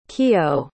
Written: Kiyo

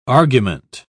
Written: argument

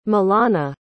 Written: malana

